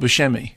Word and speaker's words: Buscemi: No, but incidentally, Buscemi, not Buscemi. I asked him, I said, well, how do you pronounce it? And he said, Buscemi.